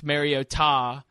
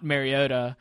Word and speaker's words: Mariota: Everybody has quickly figured out how to pronounce Mariota, not Mariota.